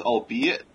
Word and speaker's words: albeit: Also, it's albeit, three syllables, long O.